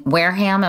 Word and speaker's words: Wareham: You grew up in Wareham, am I pronouncing it correctly? – That's right.